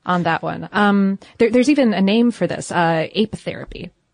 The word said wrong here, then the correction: apitherapy: Did I say that right? Yeah? Apiary? Apitherapy? Yeah, totally.